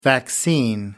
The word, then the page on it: vaccine: American English